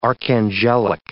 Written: archangelic

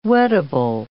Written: wettable

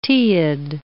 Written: teiid

